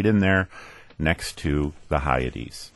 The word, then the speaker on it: Hyades: Is it Hyades? – Hyades.